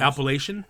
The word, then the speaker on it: Appalachian: You know, these Alapakia. How do you pronounce? I always struggle with this word, the mountains. How do you pronounce? – Appalachian. – Appalachian. Yes, that's it.